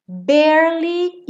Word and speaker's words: barely: Barely even friends. Nesse barely, o E do meio não é pronunciado. Então, você enrola a língua para o R, bear, e já vai para o li, barely.